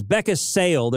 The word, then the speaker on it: Beckinsale: I guess it's Beckinsale. There's no D in it, so I mispronounced her name.